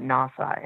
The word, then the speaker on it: NAWSA: People just pronounce that acronym and they call it NAWSA, N-A-W-S-A.